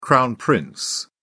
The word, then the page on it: crown prince: British English